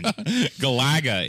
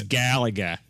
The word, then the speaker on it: Galaga: Galaga is actually how you pronounce it. It's Galaga. And anyone that says otherwise is a fool.